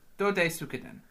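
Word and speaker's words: Dodes'ka-den: So, Dodsekaden. – Dodsekaden. – I think, yeah, and give every syllable the same stress, pretty much.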